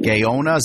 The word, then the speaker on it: Gaona: The Bulldog defense, anchored by Alex Nelson, along with fellow All-American Damian Gaona, is that how you say his name, from Chile? – That is correct.